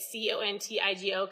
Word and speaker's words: Contigo: C O N T I G O, Contigo.